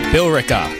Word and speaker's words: Billerica: There's an E in there. – Billerica. – Billerica, maybe? – Billerica. – Oh, that makes sense. Just leave out the E.